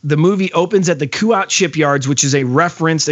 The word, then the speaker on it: Kuat: Opens up that, I don't know how you guys say this, because there's no good pronunciation, but Kuat. – Kuat. Yeah. I mean, like that.